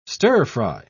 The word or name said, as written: stir-fry